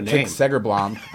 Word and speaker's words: Segerblom: That Tick Seblom. – What a Tick Segerblom.